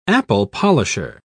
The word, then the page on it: apple-polisher: British English